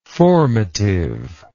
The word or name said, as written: formativeness